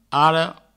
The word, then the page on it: ara: aro